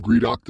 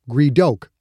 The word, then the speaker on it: Greedoka: I am Greedock the Splithead no longer. I am Greedoka. – She changed the pronunciation slightly. Greedock was now Greedoka. – Ah, the ah sound.